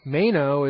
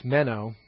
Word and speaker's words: meno: Now, meno is a word we've actually had in, and I'm pronouncing it wrong, it's meno.